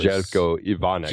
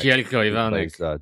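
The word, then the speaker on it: Željko Ivanek: I loved the short and tragic appearance of Zeljko Ivanovic, or Ivanek. I can't really ever say his name. – Oh, he was Zeljko Ivanek. – Zeljko Ivanek.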